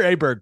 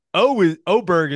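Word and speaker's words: Åberg: You'll hear Aberg quite a bit, but Oberg is the proper way to say it, right? It's the A with the little O on top of it.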